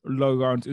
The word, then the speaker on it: LaRont: LaRont.